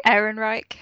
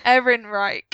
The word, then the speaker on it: Ehrenreich: Sorry, Aaron Reich. – Ehrenreich.